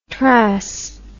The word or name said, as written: trass